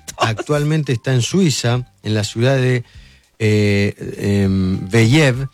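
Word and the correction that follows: Vevey: sobre el lago Lemán, en el cantón de Vaudou. ¿Lo dije bien? – Bebey y el cantón de Vaud.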